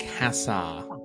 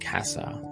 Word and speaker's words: Cassar: No, actually it's it's pronounced Cassar. – Darn it. I was way off, huh? – Yeah. Yeah, no, apparently it is derived from way back in time, derived from Caesar, apparently. But no, it's taken on a modern pronunciation, just Cassar, yeah.